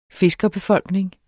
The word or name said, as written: fiskerbefolkning